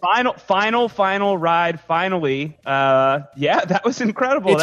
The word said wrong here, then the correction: finale: It's pronounced finale. – Finale. Oh, sorry. Yes. I've been pronouncing that wrong.